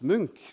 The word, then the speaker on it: Munch: Så de insisterte på at det skulle uttales Munch.